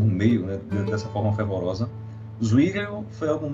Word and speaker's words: Zwingli: É, Urico Zinglo. – Ah, Zwingli.